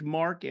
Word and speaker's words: Mark: Keith, K-E-I-T-H, Mark, M-A-R-K, and I'm the founder and president of Hunter Nation, H-U-N-T-E-R, capital N-A-T-I-O-N.